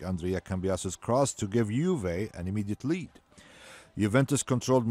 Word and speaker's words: Juve: That is Juve. – That's Juve. You said it right.